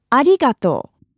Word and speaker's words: arigato: In Japanese, thank you is arigato.